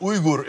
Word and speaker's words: Uygur: And it's actually pronounced Uygur in Turkish.